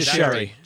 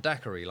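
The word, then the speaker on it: Dicharry: Is it Jay Dickery or is he Dickery? How do I pronounce it? – Jay DeSherry. – Jay DeSherry. Yeah. Not Dickery, like the drink.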